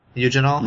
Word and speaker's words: eugenol: Eugenol? How would you guys pronounce that? – Eugenol.